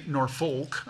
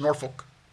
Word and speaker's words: Norfolk: When I flew into Norfolk for the, and I found out I can't say Norfolk, I get corrected very quickly out there. It's Norfolk. You get, got to be careful how you say it, but you know, you got to say it kind of fast.